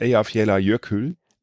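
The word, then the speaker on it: Eyjafjallajökull: nicht, ob ich ihn richtig ausspreche, aber der Vulkan heißt Eyjafjalla Jökull. – So heißt der Mist, das hätten wir auch wissen können. Eyjafjalla genau. Jökull, genau. – Jökull. Ja, so, äh, Jökull. Ja. Der Jökull. – Eyjafjalla Jökull, ja.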